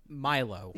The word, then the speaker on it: Milo: Is M-I-L-O. – Milo. – No, he pronounces it Milo in the movie.